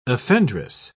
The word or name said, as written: offendress